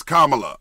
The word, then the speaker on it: Kamala: I always want to say Kamala. – Kamala. – Kamala. Kamala. I always want to say. – It's Kamala.